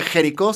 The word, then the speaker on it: Jericho: Se escribe Jericho, se pronuncia Jericho.